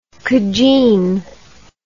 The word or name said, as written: cujine